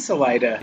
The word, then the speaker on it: Salida: It is Salida, Yeah. – but I'm like looking at a map, I'm thinking that's not Salida. That literally means exit in Spanish, and nobody would pronounce that Salida.